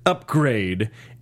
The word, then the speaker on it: Upgrayedd: So Upgrade is her pimp, and his name is spelled UPGRAYEDD. – Yep. – Upgrade.